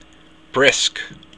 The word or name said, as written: briosc